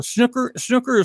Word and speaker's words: snooker: Is that called a snooker or snooker? – What's you can't even say it right. You have no right to... You have no right to talk about something you can't even pronounce. Snooker.